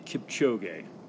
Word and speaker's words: Eliud Kipchoge: It's Eliud Kipchoge.